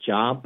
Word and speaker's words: Job: You know, it's spelled J-O-B, like it looks like the word job, but it's pronounced Job.